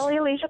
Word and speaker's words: Alicia: Alicia, but you know, depending on the accent, I'm fine with Natalia.